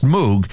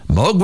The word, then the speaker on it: Moog: In 1953, physics engineer Robert Moog, whose name is usually mispronounced Moog, started a company to manufacture theremins. Moog